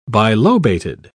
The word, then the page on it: bilobated: British English